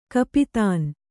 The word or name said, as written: ಕೂಪತನ